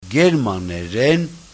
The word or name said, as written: germaneren